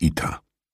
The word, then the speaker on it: Ita: Aber die meisten meiner Freunde und Bekannten, sie sind nicht zahlreich, muss ich gestehen, nennen mich Ita. Ita mit der Betonung auf der ersten Silbe, wie Peter.